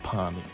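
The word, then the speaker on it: parmy: And yes, it's definitely pronounced palmy.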